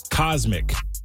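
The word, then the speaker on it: CosMc's: He was called Cosmic. Does that make sense? – Yes. – cosmic, like outer space. – Oh, yeah. – But it's spelled COS, capital MC, Cosmic.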